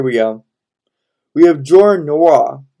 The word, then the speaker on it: Nwora: Nwora. – No. Hold on. – Nwora.